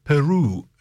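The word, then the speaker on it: Peyroux: And that was Madeleine Peru, an American. I'm pronouncing her name like a French, like it's French, which it's a French name.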